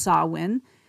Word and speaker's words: Samhain: Of course, it's spelled S-A-M-H-A-I-N, pronounced Samhain.